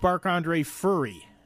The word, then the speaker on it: Marc-Andre Fleury: You got to get his name right. – What? – It's Marc-Andre Fleury.